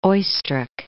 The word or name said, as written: Oistrakh